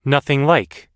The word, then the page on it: nothing like: American English